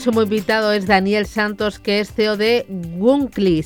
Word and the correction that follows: Wonkly: ¿Se dice así, Daniel? Buenas tardes. – Muy buenas tardes. Efectivamente, así se dice, Wonkly.